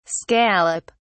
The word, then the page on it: scallop: British English